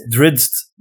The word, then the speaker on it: Drizzt: It's Drizzt.